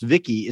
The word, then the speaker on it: VICI: Also known as V-I-C-I, pronounced Vicky, is an acronym for Voice Input Child Indicant, whatever that means.